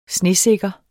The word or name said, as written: snesikker